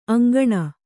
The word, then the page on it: ಅಂಕೋಣಿ: aŋkōṇi